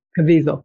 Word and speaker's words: Caviezel: Cavazzol. I can't pronounce his name correctly. Anyway, another Caviezel. Rabbit. Caviezel. Thank you.